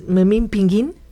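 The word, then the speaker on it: Memín Pinguín: De niños, muchos de nosotros cometimos el error de pronunciar Memín Pingüín como si tuviera diéresis, pero con el tiempo nos dimos cuenta del error de pronunciarlo sin diéresis. – ¿Es Memín Pingüín? – Ah, pues sería, ¿no?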